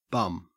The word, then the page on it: bum: Australian English